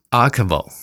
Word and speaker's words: ARKVO: 请问这个 A R K V O 要怎么念才好？Archivo。 – 那它是为什么如此取名呢？ – 它它是从这个水的世界语哈，有一种世界语哈，像英文是 water，、嗯、然后世界语的水是这个 archivo a 这个 a k v o，然后我加一个 r。